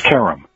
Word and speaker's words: Karam: Yeah, you were close, or I think you got it right half the time. It's pronounced Karam. – Karam, okay. – And I'm told that that's an Americanized mispronunciation of the original, but it works for us.